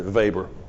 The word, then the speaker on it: Weber: It's spelled Weber, but his mama didn't teach him any better, so he pronounced it Weber.